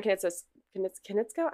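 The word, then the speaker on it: Konietzko: So Brian Kanitsko, am I saying this right? – Yes.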